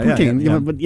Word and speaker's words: Poutine: Poutine? How do you say that? – Poutine, yeah. – Poutine.